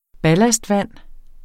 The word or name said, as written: ballastvand